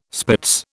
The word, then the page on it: spitz: British English